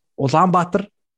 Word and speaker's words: Ulaanbaatar: It's pronounced Ulaanbaatar.